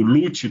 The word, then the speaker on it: Luce: A gente escreve a palavra como Luce, a pronúncia é Lute porque é italiano, e tem uma, um porquê.